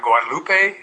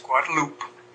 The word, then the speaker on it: Guadalupe: And you notice my pronunciation of Guadalupe? It's not Guadalupe, it's Guadalupe.